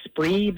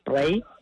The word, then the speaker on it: Spree: About an hour and a half from Berlin, in a little area that is spelled like Spree, but they say it like Spray, the Spree Forest area, and Spree, and that sounded Irish the way I said it, but they say Spray, but it's more like Spray on the A, but it is a lovely area.